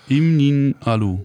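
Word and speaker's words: Im Nin'alu: Spricht man das so aus? Ja. Im Nialu. – Ja, ja, ganz genau.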